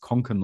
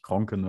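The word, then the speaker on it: Kånken: Das heißt Konken und nicht, oder Kenken und nicht Kronken, weil da ist gar kein R, weil ich will dass wir es jetzt beibehalten.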